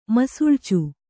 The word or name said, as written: ಮಸುಳ್ಚು